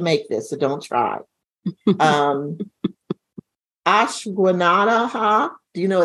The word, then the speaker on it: Ashwagandha: It's Ashwagandha.